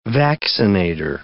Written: vaccinator

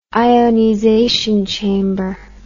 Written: ionization chamber

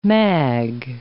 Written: mag

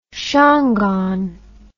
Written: shangaan

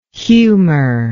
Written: humour

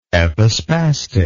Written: epispastic

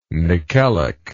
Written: nickelic

